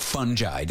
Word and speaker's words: fungi: So we are here with entomology professor at the University of Maryland, Raymond Suntledger, who knows a thing or two about fungi. Did I even say that properly? Is it fungi? – You did that very well for young man.